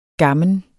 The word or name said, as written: gammen